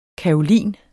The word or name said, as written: kaolin